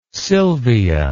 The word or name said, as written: silvia